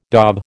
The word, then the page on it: dob: American English